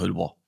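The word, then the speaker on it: Heudeber: Oder wie man im Harz, glaube ich, sagt, Heudebeer. – Heudebeer. – Eine Silbe.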